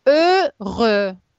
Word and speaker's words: heureux: Теперь être heureux. Аж зачеркиваете, аж не читается никогда. Heureux. А X тоже не читается.